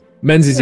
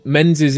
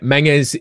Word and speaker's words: Menzies: So it's Menzies is the anglicized way of pronouncing it. Menges is the Scottish way of pronouncing it. And you can already hear my accent coming on the Scottish way of doing it. But it's honestly Menzies.